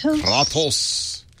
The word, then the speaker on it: Kratos: Code name of the week is Kratos. Is that the correct pronunciation? – Kratos.